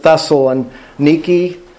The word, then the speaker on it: Thessaloniki: It's called Thessalonica, although sometimes they pronounce it Thessaloniki.